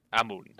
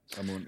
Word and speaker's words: Amun: After that, he claims to be the son of Amon um, Am- Amon, Amon, Amun. That's the right Just pronunciation. Say, yeah, I got it. Amun.